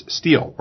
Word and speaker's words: Stihl: and that's Stihl, or Stihl, depending on how you want to pronounce it, S-T-H-I-L.